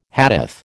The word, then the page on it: Hadith: American English